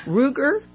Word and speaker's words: Ruger: Ruger. – Yes. – R-U-G-E-R.